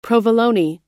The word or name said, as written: provolone